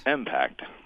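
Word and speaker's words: MPACT: do you say it Impact or Impact? – Impact. – Impact, because it's spelled M-PACT. – That's correct.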